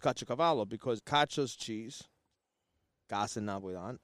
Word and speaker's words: Caciocavallo: Caciocavallo is the Neapolitan pronunciation.